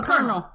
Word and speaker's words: colonel: Is it Colonel? Am I saying that right? – A Colonel. – Colonel. – You would never know to look at that word.